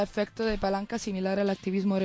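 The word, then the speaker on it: hacktivismo: Yo creo que se lee activismo, que suena como activismo, pero se escribe con la H.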